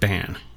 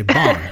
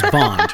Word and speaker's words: Bann: It's interesting too, because my family actually pronounces it Ban. And I think that's kind of like nasally for me. So sometimes I'll say Bon. Because it sounds like James Bond, right?